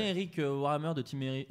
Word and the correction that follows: Wareheim: Warhammer, pas du tout. Ça, c'est Warhammer. – Non mais. Il prononce mal. Non Eric. – Wareheim.